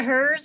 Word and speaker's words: Herz: Julia Hers, pronounced opposite of his.